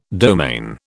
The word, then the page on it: domain: British English